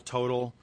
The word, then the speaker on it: Total: I mean, T-O-T-A-L, Total, if you will. Trades on the New York as T-O-T. – Well, what is the correct pronunciation? – I think Total.